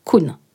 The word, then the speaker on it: Koen: J'ai écrit l'épisode d'aujourd'hui en collaboration avec Anaïs Kuhn. Attention qui s'écrit K-O-E-N, mais qui se prononce Kuhn.